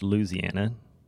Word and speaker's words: Louisiana: Which will run from what is today Louisiana, pronounced Louisiana.